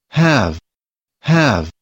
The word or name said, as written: have